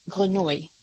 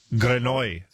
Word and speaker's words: Grenouille: If Jean-Baptiste Grenou, was that a good pronunciation? – Is it G-R-E-N-O-U-I? – U-I-double-L-E. – I think if it's the French word, it's Grenouille. – Grenouille, thank you.